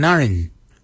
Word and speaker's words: ngariny: Woolij is spelled W-O-L-I-D-J. Woolij. Woolij is your calf muscle. Your ribs is your narin. Narin is your ribs. Narin is spelled N-G-A-R-I-N-Y.